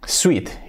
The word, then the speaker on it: suit: Pero suit, pues no es traje, suit es dulce, escrito S-W-E-E-T, suit. Eso sí que sería pronunciado suit, pero sería dulce. Traje se escribe suit y la pronunciación es suit, suit.